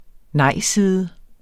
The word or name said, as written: nejside